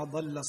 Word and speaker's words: ضل: ما شاء الله تبارك الله محمد، قراءة طيبة مباركة، ولكن هناك بعض التنبيهات في مثل قوله تعالى ما ضل صاحبكم وما غوى، انطقها بالضاد وليس بالضاء.